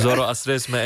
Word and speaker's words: Zoroastrisme: Zoroastrisme en islam.